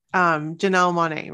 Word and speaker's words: Janelle Monáe: Janelle Monáe, right? That's how you say it.